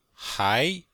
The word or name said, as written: hai2